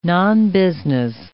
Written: nonbusiness

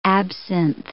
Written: absinthe

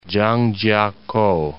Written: Zhangjiakou

